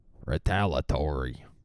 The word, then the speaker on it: retaliatory: Retaliatory. They're forgetting the whole fucking E syllable. E-a-tory. Retaliatory, you dumb fuck news anchors. So, retaliatory. Six syllables. Don't drop one. Don't drop the E.